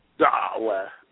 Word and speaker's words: Dawah: We have the word Dawah, Dawah, Dawah. And most people pronounce that very incorrectly.